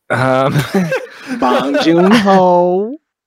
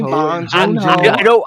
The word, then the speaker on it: Bong Joon-ho: Bong Joon-ho. Say it with me, Bong Joon-ho.